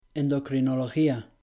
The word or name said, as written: endocrinología